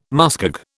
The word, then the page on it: muskeg: British English